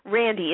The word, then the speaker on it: Randi: Randy is spelled R-A-N-D-I.